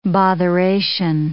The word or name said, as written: botheration